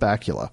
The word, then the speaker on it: Bacula: And then on the server side, there's an application called, it rhymes with Dracula. It's called Bacula.